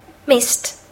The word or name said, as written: mist